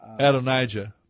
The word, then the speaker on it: Adonijah: Now, that's interesting because there's even a Jewish song that people sing in temples, synagogues, that's Adonolam, Adonolam, and you see, and that's why it's pronounced from the Hebrew, Adonijah.